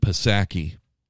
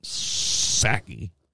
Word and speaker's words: Psaki: That woman, that's Jen Psaki, where the P is silent. Psaki.